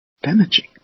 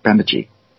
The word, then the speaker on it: Bemidji: It's easy to pronounce it Bemidji, but that would be wrong. – Yeah, I knew that the middle syllable was the one to be accented, but I think just fracture it, and that's what I did. – It's the first one. It's the first one gets emphasized.